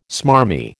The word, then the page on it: smarmy: American English